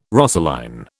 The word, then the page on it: rosaline: British English